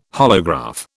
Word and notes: holograph: British English

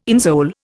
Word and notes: insole: Indian

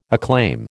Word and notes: acclaim: American English

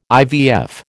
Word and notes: IVF: American English

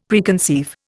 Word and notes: Preconceive: Indian

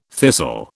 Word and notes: thistle: British English

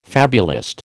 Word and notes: fabulist: American English

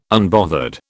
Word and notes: unbothered: British English

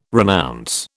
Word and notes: renounce: British English

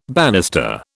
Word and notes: bannister: British English

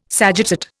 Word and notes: sagittate: Indian

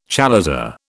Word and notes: chalaza: British English

